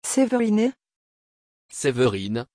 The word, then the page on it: Severine: French